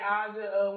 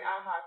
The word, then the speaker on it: Aja: A lot of people do call me Aja. When I was in California, they called me Aja because of the J.